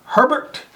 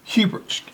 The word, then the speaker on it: Hebert: So, our garden spotlight this week is from Joyce Marie Herbert, and she is De Quincy, Louisiana. – Herbert or Hebert? – Hebert. H-E-B-E-R. – Hebert. – Hebert. Hebert, thank you so much.